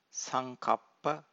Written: sankappa

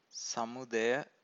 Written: samudaya

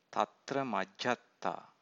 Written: tatramajjhattata

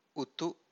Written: utu

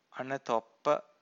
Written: anatoppa

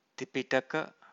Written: tipitaka

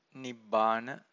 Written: Nibbana

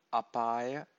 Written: apaya

